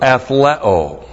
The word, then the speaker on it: athleo: Then the verb athleo. A-T-H-L-E long O.